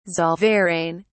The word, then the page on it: zollverein: British English